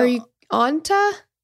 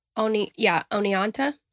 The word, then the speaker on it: Oneonta: Are you Onta? – Oneonta.